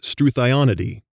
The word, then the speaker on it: Struthionidae: of the ostrich family is Struthionidae. Some might pronounce this word Struthionidae, so thigh instead of thee.